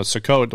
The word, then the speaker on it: Sukkot: Anyway, so Sukkot, the way we're supposed to pronounce it.